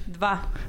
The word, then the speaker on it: dva: Part va. – How you spell that? – V-A? – Dva. D-V-A. – Oh, D-V-A. What? – Dva. – Dva. – There's a D in va? – Yeah, dva.